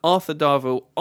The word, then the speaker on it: Darvill: The guy who plays Rory, whose name's Arthur Deville. Is that how you pronounce his name? – Davil. – Darville? – It, it will do. Arthur Darvill. I thought he was superb.